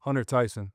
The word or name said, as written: Hunter Tyson